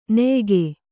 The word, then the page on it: ನೆಯಿಗೆ: neyige